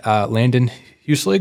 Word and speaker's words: Landon Huslig: And today I'm joined by my good friend, Landon Huslig.